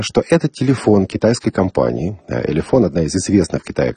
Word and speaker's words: Elephone: А в этом описании устройства написано, что это, внимание, Elefon P2000. Elefon пишется E-L-E-P-H-O-N-E, дальше буква P-2000.